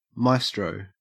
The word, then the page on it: maestro: Australian English